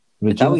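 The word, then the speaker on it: Reggina: And sign for Regina. – Who? – Am I saying that right this time? Regina Italian club? – Yeah.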